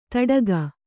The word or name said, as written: ತಡಗ